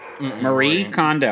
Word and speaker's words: Marie Kondo: Is it Maria? Marie Kondo. I said it right.